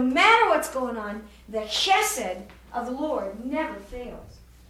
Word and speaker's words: chesed: Now sometimes when they write it, they leave off this C and leave off this H and just spell it H-E-S-E-D with a dot under those two because of the kind of Hebrew letters they are. Chesed, C-H-E-S-E-D-H.